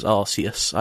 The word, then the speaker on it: Arceus: So Pokemon Legends Arceus, I believe, is how it's pronounced.